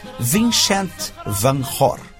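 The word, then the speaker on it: Vincent van Gogh: São inúmeros, mas vamos mencionar apenas três: Hieronymus Bosch, Rembrandt e, no século XIX, o fabuloso Van Gogh, cujo nome se pronuncia na língua holandesa Vincent van Hoor.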